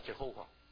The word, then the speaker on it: Jehovah: 是以发节目，以发节目啊，是把这个 Adonai 这个主这个字的母音移到刚才我们看到这个亚威这个字的下面，就产生个新的读音叫 Jehovah。